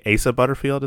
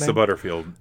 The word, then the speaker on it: Asa Butterfield: Also, Hugo is played by, I think, Asa Butterfield is how he pronounces his name. – Asa Butterfield.